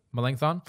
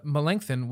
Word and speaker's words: Melanchthon: Melanchthon. – Did I do it? Melanchthon.